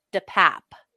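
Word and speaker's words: DePape: Oh, the only time he spoke during the arraignment Tuesday was to tell the judge how to pronounce his last name, which is DePap.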